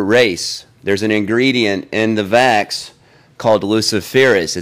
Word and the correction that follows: Luciferase: It's mispronounced. It's actually Luciferase.